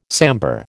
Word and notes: sambar: American English

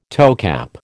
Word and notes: toecap: American English

